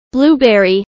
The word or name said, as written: blueberry